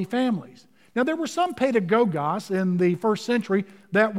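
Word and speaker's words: pedagogos (παιδαγωγός): In verse number 24 in verse number 25, he uses the term tutor, or in the Greek text, pedagogos. Pedagogos is the term. And uh, I want you to learn to say that. Uh, repeat it after me, syllable by syllable. Pay, Da, go, gos. Well, bless your neighbor by saying pedagogos.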